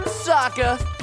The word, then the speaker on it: Sokka: That's right, I'm Sokka. It's pronounced with an akka.